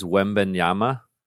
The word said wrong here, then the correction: Wembanyama: Victor oh, Wembanyama.